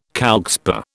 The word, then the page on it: calcspar: British English